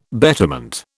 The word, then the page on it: betterment: British English